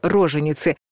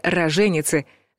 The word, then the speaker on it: роженицы: Ошибочное ударение замечено и в слове «роженицы», которые на самом деле «роженицы», женщины во время родов или только что родившие.